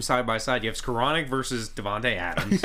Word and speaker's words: Skowronek: And when uh, I can't even say his name, Skronik Skowronek. Skowronek, there you go.